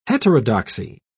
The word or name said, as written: heterodoxy